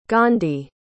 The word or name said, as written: gandhi